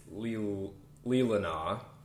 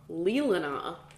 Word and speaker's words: Leelanau: It's Leelanau. – Leelanau.